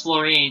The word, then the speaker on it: Florine: Hi, I'm Hans Florine. Do you want me to spell that? – No, you don't have to spell that, Hans. Thanks, though. – Pronounce like what you're standing on.